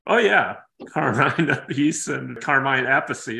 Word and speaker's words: Appice: It's Carmine Appice and Vinny Appice.